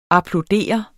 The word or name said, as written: applaudere